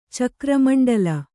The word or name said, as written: ಚಕ್ರಮಂಡಲ